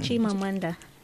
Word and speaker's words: Chimamanda: Chimamanda.